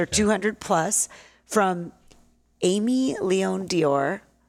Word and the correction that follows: Dore: or Dore. I've said it wrong, not Dior.